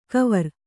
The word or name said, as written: ಕವರ್